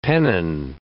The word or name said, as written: pennon